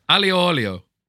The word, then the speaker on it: aglio olio: Your main course, you would like the tuna steak on lentils with the spaghetti aglio olio. – I've said it right three times here. – Yeah. – Aglio olio.